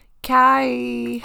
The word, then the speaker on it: Khy: Kai. – Kai. – It's spelled Kai. It's spelled K-Y-H. So just the K-H-Y. – B-I-C-T-H.